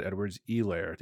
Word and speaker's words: Edwards-Helaire: Did you know this H is silent? – I did not know that, no. – I've been saying Helaire the entire time. Apparently it's Clyde Edwards-Helaire. The H is silent, so okay.